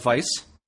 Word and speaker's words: Weisz: Did you know her name is pronounced Weisz? – Yeah.